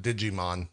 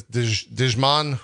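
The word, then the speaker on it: Djimon: How do you say this name? It's not Digimon. That's just awful. It's Digimon Hunso is Korath the Pursuer.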